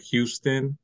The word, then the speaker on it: Houston: So it looks like Houston, but it's pronounced Houston.